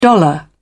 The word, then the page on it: dollar: British English